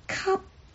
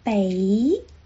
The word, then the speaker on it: 给: 接下来正音的是给，给你的给，粤语是 cup, cup, 科啊，闭唇音，科啊 cup。这个是书面语的发音，口语里面我们用北波 a 北来代替 cup。